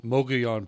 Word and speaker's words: Mogollon: Uh, Gail, let's move over to New Mexico and your uh, silver gold Mogollon property. And I hope I pronounced that wrong. That right. I hope I pronounced that right. – You got it.